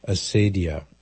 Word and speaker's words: acedia: It's called acedia, A-C-E-D-I-A. Well, some people pronounce it the more the Latin way, acedia.